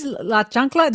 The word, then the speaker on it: La Chancla: I, what is La Chancla? Did I say that right? uh, La Chancla, it is.